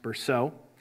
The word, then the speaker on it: Bercot: It's a book called A Dictionary of Early Christian Beliefs by David Berceau, spelled Bercot, B-E-R-C-O-T, but pronounced Berceau.